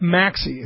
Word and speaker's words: Maxey: It's Jack Maxey. It's M-A-X-E-Y. Just double check that. Yeah, Jack Maxey. M-A-X-E-Y.